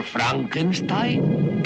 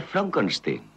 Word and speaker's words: Frankenstein: ¿Doctor Frankenstein? – Frankenstein. – ¿Me toma el pelo? No, se pronuncia Frankenstein.